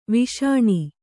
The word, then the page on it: ವಿಷ್ಣು: Viṣṇu; Kannada